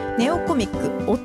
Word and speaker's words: 弟: ネオコミック、弟と書いてネオコミックと読みます。